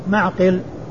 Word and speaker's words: معقل: من سواه في صحيح البخاري بهذه الصورة فهو معقل بالعين والقاف بالعين الساكنة والقاف المكسورة. ومغفل ومعقل رسمهما واحد يتفقان في الرسم ويختلفان في النطق يختلفان في الشكل والنقط ويتفقان في الرسم والصورة.